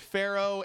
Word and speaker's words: Faarrow: These two girls, their band is named Pharaoh, and this goes, and it's not spelled like it should. It's spelled F-A-A-R-R-O-W.